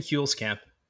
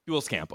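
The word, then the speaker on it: Huelskamp: I'm probably going to mispronounce his last name, Tim Hulskamp. Is that correct pronunciation there? – Very, very close. Tim Hulskamp. – Hulskamp. Okay.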